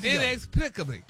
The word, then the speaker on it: inexplicably: Inexplicably. – Start. After you say NX. – NX. – All right, break it down to a syllable. – Plick. – Yeah. – Obli. – Yes. – But inexplicably.